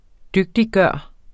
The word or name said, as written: DYGTIGGJORT